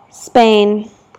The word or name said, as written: Spain